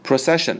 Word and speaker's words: procession: 那么先来看第三个单词 procession. Procession, 那重音在 ce 这里啊，不要念成 procession, 是 procession, procession.